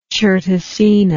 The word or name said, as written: certosina